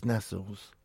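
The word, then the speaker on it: Nestlé: Because it's Nestles. Oh, it just grates on me, people who say Nestle. – That's the correct pronunciation. – No, it's Nestles.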